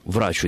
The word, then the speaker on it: врачу: Я бы, наверное, сказал все-таки «врачу исцелися сам». Мне кажется, что ударение здесь нужно сохранить на первый слог.